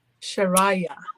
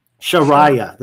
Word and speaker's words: Sharayah: Can you say it one more time? – Sharayah. – Sharayah. That sounds so much more beautiful than how I'm pronouncing her name.